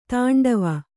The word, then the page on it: ತಾಂಡವ: tāṇḍava